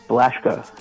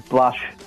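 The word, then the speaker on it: Blussh: So it's like Blashko there. But if I did that, people would say Blashko, and I really don't like Blashko at all. So just phonetically spelt it out as Blush, and then I just added the little apostrophe because we just thought we'd be a bit quirky.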